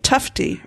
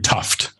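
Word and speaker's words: Tufte: Tufty, right? Is that how you would say it? – Edward, named after Edward Tuft. – Tuft. Okay.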